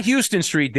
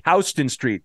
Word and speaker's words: Houston Street: The neighborhood's changed down there on Houston, not Houston Street, Danny, Houston Street. – Houston, you know, yeah. – Even though it's spelled Houston, it's pronounced Houston's.